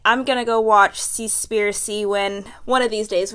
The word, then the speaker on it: Seaspiracy: Yeah, Seaspiracy.